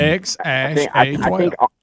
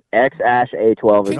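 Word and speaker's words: X Æ A-12: How do you say this thing? – X Ash A-12. – Really? – Yeah, X Ash A-12.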